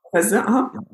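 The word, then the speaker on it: Gaza (غزة): Was Gaza, which in Arabic is pronounced Gaza, did I say that correctly? – Gaza.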